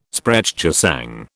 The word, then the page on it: Sprechgesang: British English